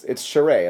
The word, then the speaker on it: Charest: It's Charest. It's like the beginning of charades, except without the days at the end.